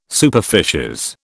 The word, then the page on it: superficies: British English